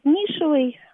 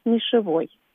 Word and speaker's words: нишевый: Язык меняется, жизнь наша меняется, язык это, эти изменения отражает, появляются и новые слова, и новые значения у слов, и вот хороший этому пример слова, как мы его привыкли произносить, нишевый, хотя напомню нашим слушателям, что пока единственная норма, закрепленная в нормативных словарях, это вариант нишевой, хотя так редко кто говорит. Но помнить об этом не вредно.